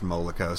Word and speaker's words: Moloko: I think it's pronounced Moloko, sorry.